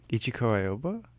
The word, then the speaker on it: Ichiko Aoba: Ichiko Aoba? – I think that's, yeah.